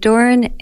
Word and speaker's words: Doran: My name's Kate Doran, and my last name is spelled D-O-R-A-N.